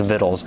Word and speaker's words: victuals: Yeah, in case you didn't know that, by the way, victuals, it's not actually pronounced victuals, it's pronounced vittles, but it doesn't look like that at all.